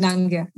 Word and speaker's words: Nangia: And the last name is Nangia, which most people pronounce as Nangia, but it's incorrect. So it's Deepali Nangia. There you have it.